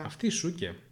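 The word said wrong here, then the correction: Sookie: Σούκι. – Σούκι, τέλος πάντων.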